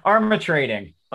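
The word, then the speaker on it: Armatrading: Armitrading. Joan Armitrading. – Love and affection. I just love that song. Do you love that song? – I had not heard that song. I do know Joan Armitrading, but I did not know that song.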